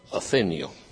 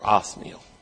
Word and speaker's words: Othniel: Now the first one we're going to look at, the first judge, is Othniel. Or Othniel, depending on who's pronouncing it. Really, there's no I in there between the H and the N, so it's Othniel, but some call it Othniel.